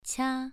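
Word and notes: qia: tone 2

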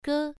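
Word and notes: ge: tone 1

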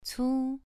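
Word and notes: cu: tone 2

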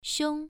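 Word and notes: xiong: tone 1

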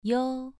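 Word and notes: yo: tone 2

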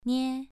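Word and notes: nie: tone 2

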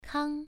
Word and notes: kang: 1

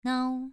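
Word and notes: nao: tone 2

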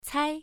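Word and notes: cai: tone 1

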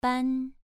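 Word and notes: ban: tone 2

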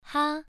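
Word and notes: ha: tone 1

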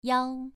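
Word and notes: yao: tone 2

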